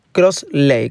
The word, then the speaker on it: Crossley: Se dice así, Cross Lake, con Y al final.